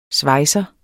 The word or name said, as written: svejser